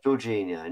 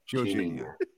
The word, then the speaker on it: Jorginho: Yes, Jorginho, not Jorginho.